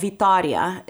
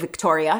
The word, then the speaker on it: Vitória: So then we start looking at places in Brazil, and the city we lived in is called Vitória. You can pronounce it Victoria if it makes you happy. – No, say it the right way.